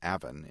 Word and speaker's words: Avon: The River Avon, pronounced Avon in Ontario, ran through it.